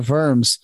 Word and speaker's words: Worms: Like when Luther is on his way to and from the Dia de Worms, and yes, that is how you pronounce it.